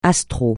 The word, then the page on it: astral: francais